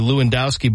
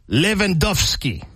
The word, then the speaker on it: Lewandowski: So I'm going the acceptable Americanized alternative of the last name, which would be Lewandowski, but apparently it is pronounced because he's played in Poland, you know.